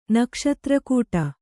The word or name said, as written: ನಕ್ಷತ್ರಕೂಟ